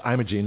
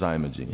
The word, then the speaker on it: Imogene: It's Imogene, not Imogene. – It is Imogene.